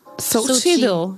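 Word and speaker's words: Xochitl: Weil ich, das ist schwierig, weil sie ja mit X vorne geschrieben wird. – Ja. – Aber wie spricht man diesen Namen einfach aus? Und jetzt? – Stay tuned. Sochi. – Sochi. Nee, du hörst hinten noch ein L.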